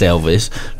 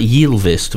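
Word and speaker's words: Ylvis: I don't know if it's pronounced Elvis. It's like Yelvis to me. It's Y-L-V-I-S is the name of the artist.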